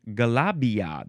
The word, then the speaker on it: galabia: Now we have the word Galabria. Galabria. You can spell it G-A-L-A. Oh, no, actually, Galabia. There's no R. Galabia. G-A-L-A-B-I-A or G-A-L-A-B-I-E-H or G-A-L-A-B-I-Y-A.